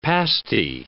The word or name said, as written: pasty